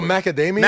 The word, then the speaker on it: macadamia: My favorite cookie is Mount Damien. – Mount Damien. – Mount Damien. I think I'm saying it wrong, but it's like a white chocolate chip. – Oh, macadamia.